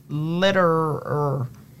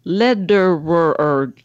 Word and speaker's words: Lederer: Her name is Elizabeth Lederer. Let, letterer.